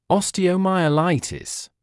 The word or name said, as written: osteomyelitis